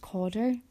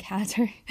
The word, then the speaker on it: Cawdor: And so it's taken place in, I think it's pronounced Cawdor, but I've been saying Cadder, but it's just outside Maryhill, near Somerston, and it's the community centre in Cawdor, and it's taken place from 1 p.m.